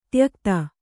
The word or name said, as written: ತ್ಯಕ್ತ